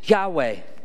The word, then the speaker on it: Yahweh: In Hebrew, the name of God would be pronounced Yahweh.